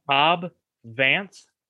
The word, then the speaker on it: Cobb Vanth: Cobb Vanth.